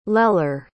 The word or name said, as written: lelar